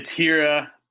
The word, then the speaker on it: Hiura: What's your outlook on Keston Hiura? How do I properly say his name? – It's Hira, and he, you know, he's extremely talented.